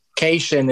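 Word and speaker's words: Kashian: But the fact that Cation is the actual pronunciation of Jackie Cation is absolutely brilliant.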